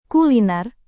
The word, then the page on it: kuliner: indonesian